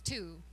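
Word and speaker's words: Tu: His name is Two, the number two.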